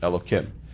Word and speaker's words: Elokim: We pronounce it, even though it's spelled with four letters, we pronounce it Elokim.